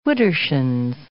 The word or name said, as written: widdershins